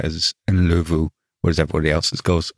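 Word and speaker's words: Ndlovu: Yeah, on that pronunciation, the very Yorkshire Undlove is how I always knew it, but I did work with someone from Zimbabwe and he pronounced it Nlovu, which is probably more accurate.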